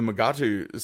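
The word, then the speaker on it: Mugato: My mind must be really weird because when I saw 14, the Mugatu skeleton, and yes, I'm using the pronunciation from the episode,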